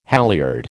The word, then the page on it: halliard: American English